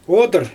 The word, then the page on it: odyr: Xyzyl